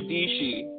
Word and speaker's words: Dishi: this girl Sarah Dishi rhymes with peachy.